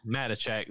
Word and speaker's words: Matichak: Matichak looks like how that's pronounced.